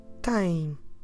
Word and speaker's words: tem: Você tem. How do we spell it? T E M. Tem. So no sh at the end this time. That makes the difference between the informal tens and the formal tem.